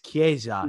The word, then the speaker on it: Chiesa: But then they'll tell, you know, you have a girl who will mispronounce someone's name, and someone will act incredulous and say, hey, it's Federico Chiesa. It's Chiesa. Yeah. He plays for Juve. You know what I mean? They'll you know, just to hammer home Chiesa, who's a guy.